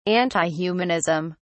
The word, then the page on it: antihumanism: British English